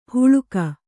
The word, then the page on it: ಹುಳುಕ: huḷuka